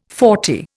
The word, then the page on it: forty: Indian